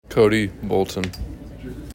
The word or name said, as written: Cody Bolton